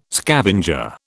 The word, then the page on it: scavenger: British English